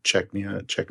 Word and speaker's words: Chechnya: Didn't you make the comment about it would be an ass move to fly to Chechnya? Am I saying that wrong? Chechnya? – Yeah, yeah. Yes.